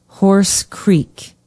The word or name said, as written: horse creek